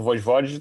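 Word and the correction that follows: Vojvoda: tá, ou sei lá, como a PVC depois me ajuda na pronúncia aí. – Voivoda. – Voivoda. Voivoda.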